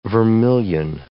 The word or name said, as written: vermillion